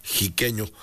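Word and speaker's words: Xiqueño: Aquí dice, ojalá y tengan grabado ese huapango chiqueño que se escribe con X, sí, y se pronuncia con J, chiqueño. Por ser de Xicotepec de Juárez, dice el 3030.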